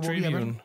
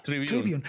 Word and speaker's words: Tribune: Entonces, sus dos primeros periódicos fueron The News World que, luego fue rebautizado como New York City Tribune, Tribune, no, sé cómo se pronuncia eso. – Tribune, Tribune.